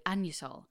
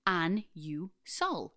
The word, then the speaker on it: Anusol: A couple of episodes ago, you said the word anusol.